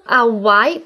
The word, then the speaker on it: Hawaii: Hawaii произносится именно со звука а, потому что в итальянском нет звука х, как у нас х, или по-английски Hawaii, Гавайи, в русском г. В итальянском остается всего лишь звук а.